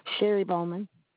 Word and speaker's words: Sherry Bowman: Sherry Bowman.